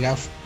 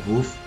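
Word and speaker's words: Guf: Pues mira, por lo que yo estuve leyendo, lo que estuve leyendo acerca de eso, se pronuncia Goof, de hecho.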